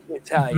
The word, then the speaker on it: Italian: Dan says, members of a certain demographic, rednecks, tend to put the emphasis on the I, hence Italian.